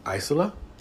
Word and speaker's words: Isla: Just call me Isla. – Isla. – See, the S throws me off now.